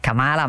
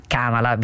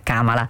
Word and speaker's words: Kamala: Kamala, perché ieri mi hanno spiegato bene che non è Kamala ma Kamala, bisogna pronunciare Kamala.